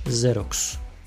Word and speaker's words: Xerox: Xerox. Yani X harfiyle yazılıyor tabi malum ben Z diyorum ama zaten X harfi doğal olarak Z şeklinde okunuyor eğer kelimenin başında yer alıyorsa.